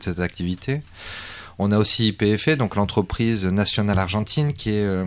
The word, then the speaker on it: YPF: Alors juste une toute petite parenthèse, on dit YPF, si vous cherchez des informations là-dessus, ouais. Ça s'écrit YPF.